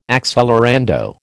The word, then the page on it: accelerando: American English